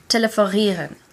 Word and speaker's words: telefonieren: To talk on the phone, so to telephone, telefonieren. – Telefonieren.